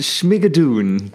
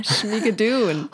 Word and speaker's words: Schmigadoon: So, und jetzt aber Schmiegadon. Oder ich weiß nicht, wie soll ich diese Serie aussprechen? Es klingt wie etwas aus einem, ich weiß nicht, Drachenuniversum. Es heißt Schmigadun. – Ah, Schmigadoon.